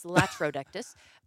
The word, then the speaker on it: latrodectus: Also, I've been pronouncing it lactrodectus. There's no C in it. – Man, who cares? – It's latrodectus. That shows like the infrastructure and how this works.